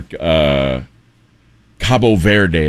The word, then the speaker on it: Cabo Verde: Well, I think things will probably go poorly for Cabo Verde, as it's pronounced.